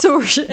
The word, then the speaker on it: Saoirse: Saoirse, exakt. Den är sjuk. – Ja, och det är liksom, det stavas inte så som det uttalas. Men jag hoppas att Kayla Setter är rätt.